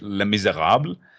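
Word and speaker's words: Les Misérables: I'm I'm not sure if I'm pronouncing it right. Les Miserables. – oh, French Les Miserables. That's correct. Yes, the French one. The um, uh, you say Binavayon yeah.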